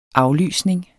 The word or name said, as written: aflysning